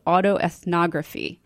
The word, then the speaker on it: autoethnography: He also sent me some very interesting articles he wrote in regard to this type of research, qualitative research, which is called autoethnography. Am I saying that right? – That's right.